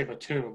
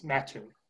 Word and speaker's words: Mattoon: Like, that's not how you say it. It's spelled Mattoon. Like, so they say Mattoon, but it's Mattoon. So it's the same thing.